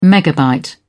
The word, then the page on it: megabyte: British English